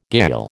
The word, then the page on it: gayal: American English